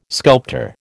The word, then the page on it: sculptor: American English